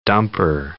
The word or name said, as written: dumper